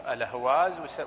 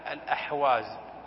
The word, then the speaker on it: الأهواز: أما كرة الأهواز نعرفه، الأهواز هي بلاد المنتفت، بلاد خزعل التي استولى عليها الروافض وأدخلوها في بلاد إيران، تسمى الأهواز ويسميها الأعاجم الأحواز لأن الها لا ينطقها الأعجم.